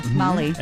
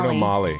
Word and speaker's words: Magli: Bruno Molly. – Molly. So the G. So the. Well, I'm saying so the G is silent.